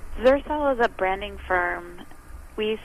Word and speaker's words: Xercel: All right, so the next guess, what is Zercel? It's spelled X-E-R-C-E-L, but it's pronounced like Xerxes, like the ancient king, the Persian king here.